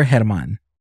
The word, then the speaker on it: German: Hey guys, my name is German Perez, and or Herman. – Or Herman. Herman. Sorry. – Well, yeah, Herman or Herman. – Yes. – Whatever. It's spelled the same way Germany is, just minus the Y. I always tell people that.